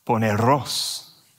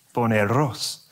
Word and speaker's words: poneros: He'll go, poneros, poneros.